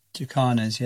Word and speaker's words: jacana: And I was asked, or I was, it was suggested that I ask about the jacana. Is that how you say it? Or jacana? – Jacanas, yeah. Jacanas, they're also called lily trotters, and it's pretty extraordinary, that story, actually.